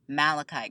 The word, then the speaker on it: Malachi: I asked you before if you were Serena or Nomi, I'm wondering if you're a Val girl or a, am I saying his name right, Malachi girl? – Yes. Yeah.